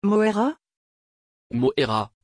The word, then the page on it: Moïra: French